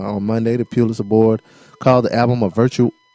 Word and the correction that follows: virtuosic: oh shit virtuosic.